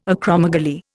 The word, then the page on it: acromegaly: Indian